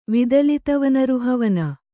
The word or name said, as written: ವಿದಲಿತವನರುಹವನ